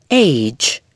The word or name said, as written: age